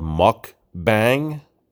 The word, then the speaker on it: mukbang: It's called muck bang, and I believe it's spelled M U K B A N G. Muck bang.